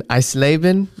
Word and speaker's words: Eisleben: So he was born in Eisleben, Germany. – That sounded pretty good.